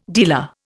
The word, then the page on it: dealer: Indian